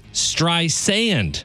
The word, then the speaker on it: Streisand: She says, my name isn't spelled with a Z. It's Streisand, like sand on the beach.